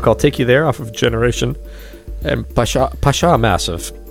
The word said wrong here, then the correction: Pacha Massive: Am I saying it right, Tim? – Pacha Massive.